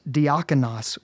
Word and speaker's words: diakonos: It comes from the Greek word meaning, a Greek word pronounced diakonos,